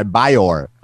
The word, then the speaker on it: Byor: This is Feeling Right by Bior. – Bior. – B-Y-O-R. Okay. – Me and So it's pronounced Byor, B-Y-O-R.